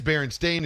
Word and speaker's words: Berenstain: It's not Berenstain. It is Berenstain is the actual pronunciation is with the A.